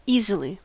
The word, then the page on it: easily: /iː.zə.liː/